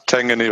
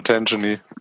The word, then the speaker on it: Tangany: Spricht man das so aus? – Ja, Tangany oder Tangany.